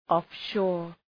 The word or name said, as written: offshore